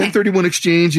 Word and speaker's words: 1031 exchange: And I hope, is it 1031 exchange? Did I say that right? – You did. Okay. 1031 exchange